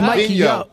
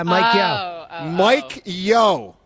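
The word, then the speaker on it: Mike Yeo: I actually thought you said it right. You said Mike Yo. – Isn't it Mike Yo? Yeah, Mike Yo.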